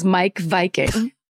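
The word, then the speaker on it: Meik Wiking: And she said, but then she also said that the author of that book that I said, remember it was like Miek Viking or whatever, his name is Mike Viking. And that's how it's pronounced.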